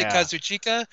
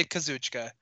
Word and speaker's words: Kazuchika: There's two ways to pronounce it because there's two dialects, two main dialects. – In Japan. Yeah. – Some people say Kazuchika. Some people say Kazuchika. They're both accepted.